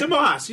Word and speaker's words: DeMoss: DeMoss.